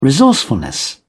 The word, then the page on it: resourcefulness: British English